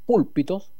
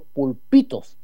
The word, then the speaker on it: pulpitos: Respecto a los que nos han escrito, nos han hecho referencia, dice que no, el auditor de, o los auditores de, que yo leí los púlpitos, porque estaba acentuado en la U, pero no en los púlpitos, es los pulpitos.